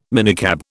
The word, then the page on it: minicab: British English